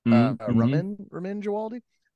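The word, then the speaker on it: Ramin Djawadi: House of the Dragon Season 1 by Raymond Gialdi, I believe is how you say his name. Ramin Gialdi.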